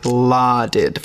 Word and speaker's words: lauded: And I think with American pronunciation, it's a little bit more like larded for something.